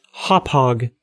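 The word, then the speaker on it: Hauppauge: I've got I was going to say hopage, but I remembered last time that someone told me it was pronounced hophog. – Whatever. – The hophog card that I've got, my $50 cheapo card.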